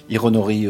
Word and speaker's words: Hironori: Si je me trompe, le fondateur est Hironi Otsuka. – Hironori Otsuka, oui, c'est vrai que c'est pas facile à prononcer le prénom en particulier.